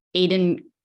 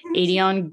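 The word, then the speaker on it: Aedion: It was when Adion first saw Aelin in the castle when they were breaking him out. And he's like, Aiden... immediately said his name wrong. Adion grinned at his queen as the entire world went to hell.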